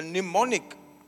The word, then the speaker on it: mnemonic: He calls it a mnemonic with a silent M. MNEMONIC.